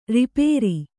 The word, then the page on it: ರೂಪಾರ: rūpāra